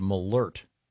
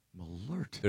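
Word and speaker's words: Malört: And this says it's pronounced Malort. – Malort. – There's a, was it an umlaut, the two dots over the O? This has a pronouncer saying Malort.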